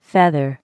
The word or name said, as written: feather